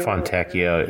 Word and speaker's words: Fontecchio: They're starting that, I don't even know how to say his name, Fonticchio. – Fontecchio. – That's right.